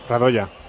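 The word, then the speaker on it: Radoja: ¿es Radoya o Radoya? – Radoya. – Vale. Balón para Johnny. – Radoya. – Parado ya. Importante para la transmisión. – Yo lo pronuncio en castellano.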